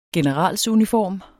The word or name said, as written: generalsuniform